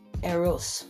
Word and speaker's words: eros: It could be called eros, and it's spelled E-R-O-S. So it's eros, and eros, it's actually not arrows. It's not supposed to be a soft sounding or Z sound. It's supposed to be the S sound is supposed to be super punctuated with a very sharp S sound.